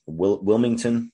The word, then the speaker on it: Wilmington: At this time in Willington, North Carolina, at the First Bank of Willington, Wilmington, I keep saying it wrong, none other than Stephen King himself tries to withdraw some cash, but the ATM, it just calls him an asshole.